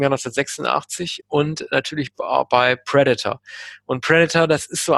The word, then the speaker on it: Predator: Oder wie Kinder, wie ich es damals waren, nicht Predator, sondern Predator gesagt haben. Man hat es damals mal falsch ausgesprochen in Deutschland. Predator, mal gesagt. Aber er heißt tatsächlich Predator, das Haupttier. Ja, also mal Predator, ne, weil das immer die Silbe, die ersten Silben falsch betont.